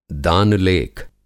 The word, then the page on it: दान-लेख: Hindi